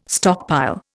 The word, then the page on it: stockpile: Indian